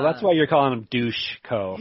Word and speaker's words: Dusko: Yes, Dushko. Dushko. Yeah. – That's how you say it. I thought it was Dusko for so long.